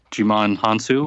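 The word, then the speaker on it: Djimon Hounsou: It's a crazy coincidence, and they both have Jimon Hansu, if I pronounced that correctly. – That's right. That's right.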